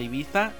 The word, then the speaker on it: Ibiza: que hacen las fiestas porque estamos en el Ibiza, o Ibiza como se pronuncia aquí en México, de América, donde todos los grandes DJs vienen a ofrecer sus fiestas aquí.